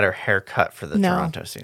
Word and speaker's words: Toronto: It's Toronto. You don't say the second T. – Sure. – It's fine. It's like Mackinac. – Yeah. – The last N-A-C is silent.